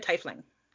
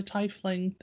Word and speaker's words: tiefling: Now, do you pronounce it tiefling or tiefling? – I've said tiefling. – Okay, yeah, there's debate, and I always say tiefling because it's E-I, so it says tiefling to me.